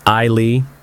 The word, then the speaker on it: Isley: The correct pronunciation is Isley. It's actually a pretty common mistake to pronounce it as Isley. That pronunciation is more like the name Isley, which is also a pretty name. Isley is pronounced with a long I sound at the beginning, rather than an I sound. It's a subtle difference, but it makes all the difference in how the name is heard.